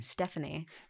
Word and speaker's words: Stefani: Because you would you pronounce it Stefani or Stephanie? – Stephanie. – Yeah. – Only because in my dad's language, things like PH don't exist. Like F is an F. – So, really? – So my dad could actually spell and understand and pronounce my name, like see his own daughter's name and get it. We spelt it that way.